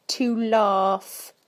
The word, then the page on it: to laugh: British English